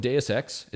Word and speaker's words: Deus Ex: This is from Deus Ex.